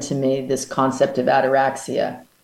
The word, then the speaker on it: ataraxia: And I was wondering what your understanding, I've heard you mention, ataxia? It's like a sort of serenity. Yeah. How do you pronounce it? Sorry. – It's called ataraxia. – Ataraxia. – I never really that's it.